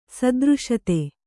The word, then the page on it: ಸದೃಶತೆ: Kannada